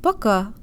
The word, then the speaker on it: Пока: Пока. Пока. Пока. Make sure the first syllable is shorter and de-emphasized, and tell your brain that here the O sounds like a schwa sound with an ah flavor.